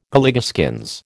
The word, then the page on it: Galligaskins: American English